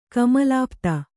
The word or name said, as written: ಕಮಲಾಪ್ತ